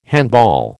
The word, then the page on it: handball: American English